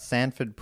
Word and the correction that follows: Stanford: Prison Experiment? – Stanford.